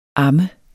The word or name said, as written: ammes